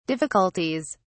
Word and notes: difficulties: British English

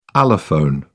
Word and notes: allophone: British English

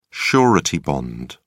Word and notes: surety bond: British English

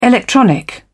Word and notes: electronic: British English

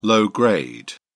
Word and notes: low-grade: British English

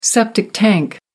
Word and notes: septic tank: American English

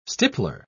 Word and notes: stippler: British English

